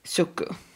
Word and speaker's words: Suqqu: S-U-Q-Q-U. Mm. Suku.